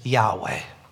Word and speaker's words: Yahweh: How do you pronounce that? We don't. We can't. There aren't any vowels. We've come to pronounce it as Yahweh. We've put in some vowels there, but the name YHWH, I mean, that's unusual.